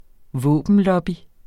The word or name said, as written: våbenlobby